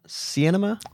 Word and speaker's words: Sinema: Sinema. – You just pronounce it cinema, mm-hmm. even though it's like S-I-E.